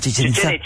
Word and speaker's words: Chichen Itza: Chichen Itza.